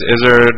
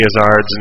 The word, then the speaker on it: Izzard: Oh, Pastorama says Izzard rhymes with lizard, but apparently Eddie Izzard's name is pronounced Izzard. And after mocking Jick for pronouncing it that way for a long time, I discovered that he was correct.